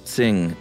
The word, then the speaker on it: Qing: By the way, in the draft podcast, Josh was having trouble pronouncing Q-I-N-G dynasty. – Uh-huh. Yeah. – Q may be roughly pronounced as T-S. – Oh. – Not exactly the same. So just say Tsing next time.